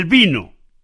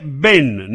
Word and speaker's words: vin: El vino. El vino se dice ven, ¿no? – Vino es vin.